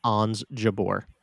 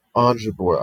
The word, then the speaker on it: Ons Jabeur: I'm interested to see what Ons Jabeur does. Did I just spell that? Did I say that? Oh my God, right? – Ons Jabeur. – Ons Jabeur.